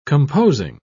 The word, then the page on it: composing: British English